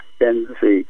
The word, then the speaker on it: Ben-Zvi: Dan Ben-Zvi. Ben-Zvi is a unique name. By the way, pronounce my name like Mercedes Ben-Zvi. Ben-Zvi.